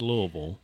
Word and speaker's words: Louisville: And it is pronounced Louisville for those folks that are listening. – Louisville. – Yeah, it's not Louisville, and I don't know who came up with that, but that is not how you say that.